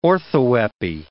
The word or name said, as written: orthoepy